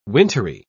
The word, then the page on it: wintery: British English